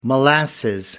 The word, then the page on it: molasses: American English